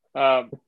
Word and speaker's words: Leicester: I'll take Leicester just to be a dick. So, You said it wrong. So it's you're wrong altogether. – I'll take Leicester. Leicester.